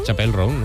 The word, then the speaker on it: Chappell Roan: Ella misma nos explica cómo se pronuncia. – Chappell. Yes. Roan. Chappell Roan. – Ah, pues lo digo mal yo. – Chappell Roan. – Chappell Roan. Apple, Apple.